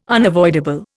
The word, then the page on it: unavoidable: Indian